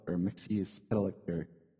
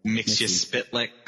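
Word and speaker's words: Mxyzptlk: Like Mixel or Mixie Spitlick. I just say Mixie Spitlick.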